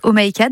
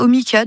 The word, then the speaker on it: Oh my cat: Comment est-ce qu'on doit prononcer le nom de l'entreprise d'ailleurs? – Alors initialement, quand je l'ai créé, c'était Oh my cat dans ma tête. Et au final, beaucoup de gens aussi le prononcent Omicat. Donc je me suis faite à l'idée.